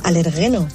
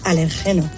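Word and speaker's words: alérgeno: Pero quiero decirle a Carlos que no se dice alergeno, se dice alergeno.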